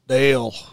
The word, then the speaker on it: Dale: Say it like Pitbull. – Dale. – Yeah, okay. That was close. – Dale.